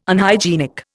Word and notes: Unhygienic: Indian

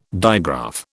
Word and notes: digraph: British English

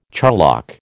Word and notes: charlock: American English

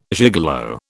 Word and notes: gigolo: British English

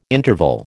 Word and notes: interval: American English

